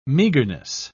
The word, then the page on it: meagerness: British English